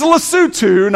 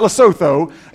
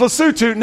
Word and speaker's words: Lesotho: It doesn't look like the way it's pronounced. Trust me, I've asked them, and I am pronouncing it correctly. Okay. It is Lesotho, not Lesotho. It's Lesotho. Now, this is the country of Lesotho.